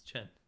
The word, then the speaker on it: Chen: Anyway, how do you pronounce the Chinese family name Chen? It's Chen.